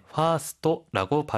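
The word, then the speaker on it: 파스토 (ファースト): 특히 퍼스트는 파스토라고 발음합니다.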